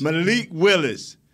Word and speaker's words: Malik Willis: Malik Willis.